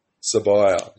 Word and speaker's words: Sabiyah: Sabiyah, yep.